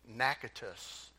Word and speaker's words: Natchitoches: It's Natchitoches. It's how they pronounce it in Louisiana.